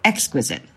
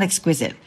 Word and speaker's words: exquisite: I would say exquisite, but she says exquisite. Okay. And that is technically, I believe, how you're supposed to pronounce it. It's not exquisite.